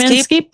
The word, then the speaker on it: Inskeep: Did I say that right? – Steve Inskeep? Yeah, from NPR.